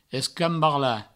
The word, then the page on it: escambarlar: Provençal; escambarla